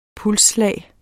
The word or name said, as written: pulsslag